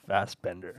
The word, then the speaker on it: Fassbender: It's Fassbender.